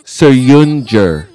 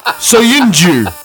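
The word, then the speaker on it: Söyüncü: I am pretty sure I have heard Soyuncu as a pronunciation on television. – Well, sure. That's what it looks like. – Yeah. – But it's definitely not Soyuncu.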